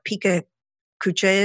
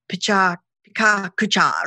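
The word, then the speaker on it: Pecha Kucha: So we did do you know what Pika Kucha is? It's actually, I'm pronouncing it wrong. Like in Japanese, it's like Pika Kucha or something.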